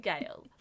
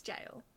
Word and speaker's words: gale: It's because it rhymes with sail! – Jail also rhymes with sail. It's the it's the ale bit, not the G bit. – I've always said gale. – It's jail.